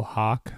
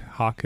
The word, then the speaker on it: Hak: It's The Real Hawk. Hawk is spelled H-A-K.